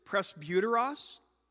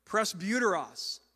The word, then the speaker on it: presbyteros: That's almost certainly not the way they pronounced it in Koine Greek 2,000 years ago, but that's how English-speaking seminary professors pronounce it, presbyteros.